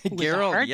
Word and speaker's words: Gerald: Hello, hello, Gerald with a hard G.